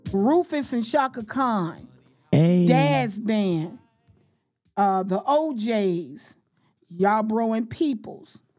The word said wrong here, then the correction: Yarbrough: Let me make sure I'm saying it right. I think so. No, it's Yarbrough and People.